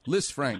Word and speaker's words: Lisfranc: Lisfranc.